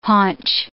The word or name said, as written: haunch